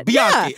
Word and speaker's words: Bianchi: How do you say it? – It's Bianchi. That's how you said it. – Yeah, Bianchi.